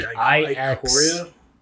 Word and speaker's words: Ikoria: I think it's Ikaroa. – It's like Ikoria. It's like IXAIAN.